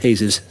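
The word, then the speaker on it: Jesús: And my mother told me that's quite a common name in Spain. It's pronounced Jesus.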